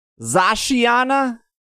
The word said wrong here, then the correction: Zacian: There's no A at the end of the Zacian. – Oh, Zacian? Sorry. Zacian and Zamazenta.